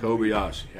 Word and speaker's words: Kobayashi: Kobe Kobayashi, or however you pronounce it. – Kobayashi. – Kobayashi.